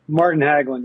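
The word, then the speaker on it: Hägglund: Martin Haugland.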